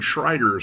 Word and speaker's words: Schroeder: This is Gene Schroeder, or Gene Schreider, sometimes pronounced.